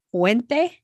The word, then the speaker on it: puente: And this is the other thing that's interesting about Spanish is if you listen carefully, it seems as though they pronounce every vowel. So puente.